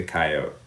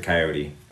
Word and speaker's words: coyote: the coyote, or the coyote, depending on how you want to pronounce it.